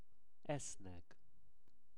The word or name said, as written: esznek